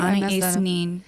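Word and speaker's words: Anaïs Nin: Anais-nin, am I saying that right? – Anais-nin.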